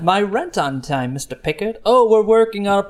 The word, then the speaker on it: Picard: It's pronounced Pickard.